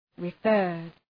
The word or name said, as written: referred